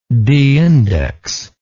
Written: deindex

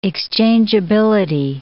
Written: exchangeability

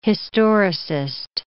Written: historicist

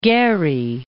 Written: Gerry

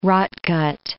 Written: rotgut

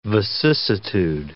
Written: vicissitude

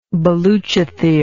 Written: baluchithere

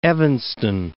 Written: Evanston

